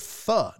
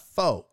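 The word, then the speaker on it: phở: It's yeah, it's pronounced fuh, not fo. F U. Fuh.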